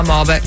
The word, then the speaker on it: Malbec: Is that how you say it? I might mispronounce the wines. – A Malbec. – Malbec.